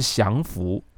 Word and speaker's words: 降服: 其实你知道，在日文里头，不管是光复还是降服，降服就投降的降，就是投降的意思。